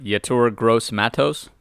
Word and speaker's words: Yetur Gross-Matos: I think I'm going to butcher this name, but Yetur Gross-Matos. – Oh, you nailed it.